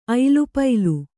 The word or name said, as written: ಐಲಪೈಲ